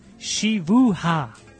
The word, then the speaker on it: siwucha: Pronounced siwucha. Siwucha po angielsku jest.